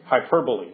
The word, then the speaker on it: hyperbole: Hyperbole. That's actually how you say it.